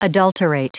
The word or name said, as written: adulterate